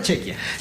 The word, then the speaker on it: Chequia: Yo creo que sí, por homenaje le van a dar uno, y va a ser contra Chequia. Ahora sí lo dije bien, contra Chequia.